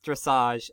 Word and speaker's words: dressage: How was I supposed to know that it's pronounced dressage and it's actually like goofy horse ballet?